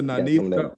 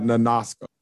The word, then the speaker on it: Nanisca: It's either Nanisha or Nanisha. I promise. Nanisha? Not Nanaska. That's an N-A-N-I-S-C-A? – I can make an I.